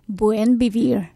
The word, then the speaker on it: Buen Vivir: And could you pronounce the name of your farm, Buen Vivir? – Buen Vivir.